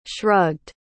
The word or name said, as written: shrugged